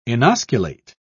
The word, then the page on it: inosculate: British English